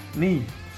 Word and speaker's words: Nathan Ni: Hello and welcome to another episode of Habsent Minded Top 25 Under 25, and we're joined today with Nathan Nye. Nee, sorry, I asked you like 2 seconds ago, Nathan. I'm sorry for the mispronunciation of your name.